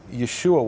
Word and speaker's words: Yeshua: So Yeshua, Y-E-S-H-U-A, is in Hebrew Yud Shin Vav Ayin. That's how the name Joshua would have been pronounced in Second Temple times, in late Second Temple times.